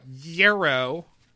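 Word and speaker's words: gyro: Do you all realize that the Greek pita wrap sandwich Euro sounds like the word hero with an H, right? – Huh? – Yeah, I know. Yero and hero sound very similar.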